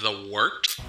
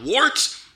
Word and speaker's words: The Wort: It is The Wort, not wart, not fort, not short, The Wort.